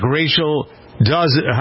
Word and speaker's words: Dolezal: All right, so the next page of this is wondering whether or not transgender law will apply to transracials, like Rachel Dolezal.